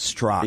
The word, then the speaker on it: Straka: Okay, now your name is spelled S-T-R-A-K-A, but you say it's just Strock. – Yes, it's just it's a cross I have to bear my entire life. – Well, it's kind of like Strock and Page, except you're the good guy. – I know. Well, yeah, that's the thing is once I explain to people that the last A is silent, they sort of switch it suddenly to Struck. And no, it's not Struck, it's Strock.